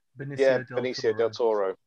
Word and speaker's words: Benicio del Toro: Benicio del Toro.